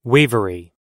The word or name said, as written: wavery